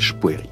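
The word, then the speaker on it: Spoerri: Il y a une discussion très longue sur la prononciation de son nom, avec laquelle lui-même s'amuse. Il est suisse, c'est un nom suisse d'origine, donc c'est Spoery ou Spoery, disons à la Suisse que je ne prononce pas bien. Lui préfère finalement la prononciation française, Spoery, qui est celle qui domine un peu dans sa... C'est-à-dire qu'en Allemagne, il se présente comme Daniel Spoery.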